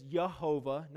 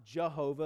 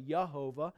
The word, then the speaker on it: Yehovah: And so YWHW could actually be pronounced as Yehovah, not Jehovah, as us English people. We see the J and we think it's a J sound. It's actually Yehovah.